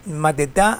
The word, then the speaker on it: Mateta: Mateta,